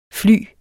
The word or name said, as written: fly